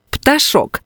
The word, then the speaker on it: пташок: У цьому слові правильно наголошувати останній склад – пташок.